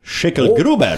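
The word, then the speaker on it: Schicklgruber: Schickelgruber.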